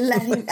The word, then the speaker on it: Larraín: Sí, Larraín. Anda, yo creo que así sí me voy a acordar, ¿eh? Larraín. Larraín. Es que es con acento en ahí, aparte. Entonces, Larraín.